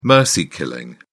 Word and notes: mercy killing: British English

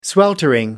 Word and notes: sweltering: American English

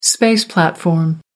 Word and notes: space platform: American English

